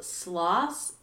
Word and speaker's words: Sloss: So today we are going to be going over what is called Sloss Furnace. – Can you spell that for me? – Yes. S-L-O-S-S. – Okay. When you first said it, I was like, sloths? – That'd sloths be pretty cool.